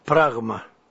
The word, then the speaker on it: pragma: How do you pronounce that? – Pragma.